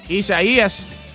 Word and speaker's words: Isaias: Isaias. – Is that close? – Is that correct? – That's it. – Isaias.